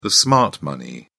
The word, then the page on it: smart money: British English